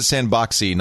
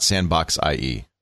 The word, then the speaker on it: Sandboxie: So you do pronounce it Sandboxy, not Sandbox IE.